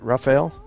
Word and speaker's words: Rafael: Tropical storm Rafael nears, did I say that right, Rafael? – Yeah.